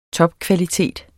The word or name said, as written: topkvalitet